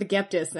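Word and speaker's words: Agapetus: And as we can tell by saying he is the first, there will be another Aegyptus, and I will stick with this pronunciation because it works for me. And I have come to this conclusion kind of how some languages say the word Egypt. So, yeah, we're going with Aegyptus, and that is it.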